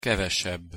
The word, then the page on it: kevesebb: Hungarian